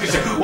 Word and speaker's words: Kashyyyk: It's pronounced Kashyyyk.